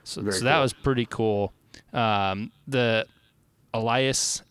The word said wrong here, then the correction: alias: words are freaking hard right now. – Yep. – That's not how you are supposed to pronounce it, and I don't know why I can't get it right. Alias. Dang it, that's it.